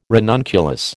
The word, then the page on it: ranunculus: American English